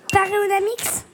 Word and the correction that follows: Panoramix: Oh, il y a une petite inversion de syllabe, mais peut-être que Paul va venir à... – Panoramix.